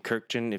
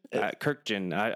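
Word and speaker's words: Kurkjian: They made some big moves, and I was listening to some Tim Kirkjian. If you don't know who Tim Kirkjian is. – Kirkjian. – I don't know. – I think it's three syllables.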